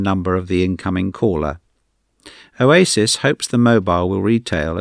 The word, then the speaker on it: Owasys: The phone is made by a Spanish company, spelt O-W-A-S-Y-S, pronounced Oasis, who hope to have their 22C handset on the UK market at the beginning of 2004.